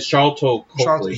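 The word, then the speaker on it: Sharlto Copley: How do you say the actor's name? – Charlton Copley.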